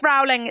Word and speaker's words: Rowling: J.K. Rowling, is that how you say her name, or is it Rowling? – I think it's Rowling. – J.K. Rowling has said some things. J.K. Rowling. No, it's Rowling. It's Rowling. Sorry.